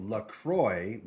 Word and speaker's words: LaCroix: So LaCroix, which is not pronounced... – in the French way.